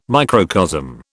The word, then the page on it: microcosm: British English